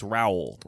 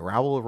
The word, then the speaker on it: Rowell: It's Rowell. – Rowell. All right. Yep. Rowell rhymes with owl.